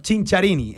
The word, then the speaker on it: Cinciarini: Eh, he tenido que buscar exactamente cómo se pronuncia, ¿no? Porque hay algunas que, igual doblan N, no. Cinciarini, el, el italiano.